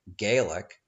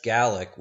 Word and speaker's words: Gallic: Unfortunately, my American accent hasn't done justice to the word Gallic, and I have unfortunately been pronouncing it Gaelic. Gaelic, of course, refers to modern Celtic languages such as Scots Gaelic, whereas Gallic would be a better pronunciation for those tribes that lived in France 2,000 years ago and before.